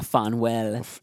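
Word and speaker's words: Phanuel: But then I had in another translation, it looked more like Samuel, but with a PH. – Yeah. Like a Fanuel. – Yeah. So, you know. – Or Manuel. – Sometimes Fanuel.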